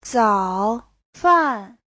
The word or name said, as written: zao3fan4